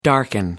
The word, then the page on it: darken: American English